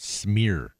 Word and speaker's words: smear: My wife gets upset with me because she says the word schmear, and I said, honey, there is no SH in the word smear.